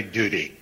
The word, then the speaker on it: Doody: Her name is Annalie, A-U-N-I-L-I-E, Hathaway, H-A-T-H-A-W-A-Y. – Thank you. Vermont, 3 votes. – Vermont, alternate Jerry Doody. Spelled like Howdy, D-O-O-D-Y.